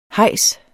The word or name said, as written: hejs